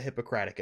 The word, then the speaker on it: Hippocratic: It's the lesser known version of the Hippocratic Oath, you know, the thing that all doctors need to. I said it wrong, didn't I? Did I say hypocritic? Like hypocritical oath? No, Hippocratic.